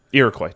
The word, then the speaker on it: Iroquois: I think that was an IROQ. How do you spell that? Is that the Iroquois? – Iroquois. What did I say? Iraqi? – Iraqi, yeah. Yeah.